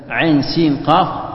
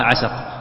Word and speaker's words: عسق: لأنها تنطق بتقطيع، يعني مثلا عين سين قاف ما تقراها عسق، تقراها بحروف بحرف حرف حرف مقطع، هذا معنى الحروف المقطعة.